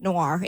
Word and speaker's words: Noir: Squid, it's pronounced noir. It's like, it means black. – N-O-I-R? – Yeah, N-O-I-R.